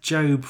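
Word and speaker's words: Jobe: So yes, the Lawnmower Man is called Job, but it's pronounced as Job from the Bible, but it's spelt with an E.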